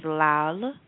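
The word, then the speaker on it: lal (लाल): So the first color is yellow, and yellow is pila, which is P-I-L-A. The second color is red, which is lal, which is spelled L-A-L-A, but it's pronounced lal.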